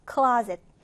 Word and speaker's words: closet: 첫음절에 강세를 두고 closet.